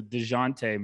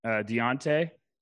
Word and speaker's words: Dejounte: And DeJounte Murray, someone DM'd me one day and said I need to stop pronouncing his name wrong. So DeJounte Murray, I'm sorry. – What were you pronouncing it? – Deontay. – What is your thing with these pronunciations? – No. I've heard Deontay as well.